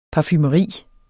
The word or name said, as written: parfumeri